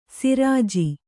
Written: ಶರಜಾ

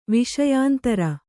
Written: ವಿಷಯಾಂತರ

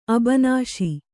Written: ಅಬನಾಶಿ

